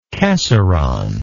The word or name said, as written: casoron